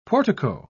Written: portico

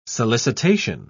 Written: solicitation

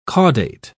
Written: caudate

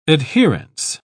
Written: adherence

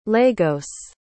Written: Lagos

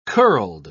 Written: curled

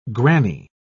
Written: granny

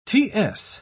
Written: ts

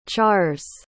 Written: chars

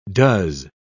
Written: does

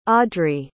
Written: audree

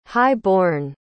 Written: high-born

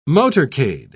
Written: motorcade

